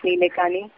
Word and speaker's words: Nilekani: Now let's wait and watch what Nilkani does. Nanda Nilekani. Yeah. I could never pronounce his last name. Is it Nilkani, Nilekani, Nilkani? Nandan. it's Nilekani.